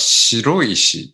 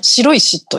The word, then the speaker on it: 白石: えー、あの、佐賀県の、えー、あれは白石って読むんですね。あ、そうです。あ、素晴らしいです。はい、白石と読みます。